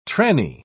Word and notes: trannie: British English

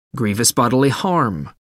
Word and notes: grievous bodily harm: American English

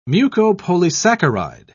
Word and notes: mucopolysaccharide: British English

